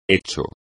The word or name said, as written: hecho